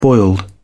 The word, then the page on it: boiled: British English